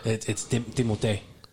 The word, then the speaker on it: Timothée: But his pronunciation of it, and people, he's like, I don't care. It's Timothée. – Timothée, yeah. – That's how you're supposed to say it. – Timoteo. – Timoteo, yeah, or Timothée. Timothée.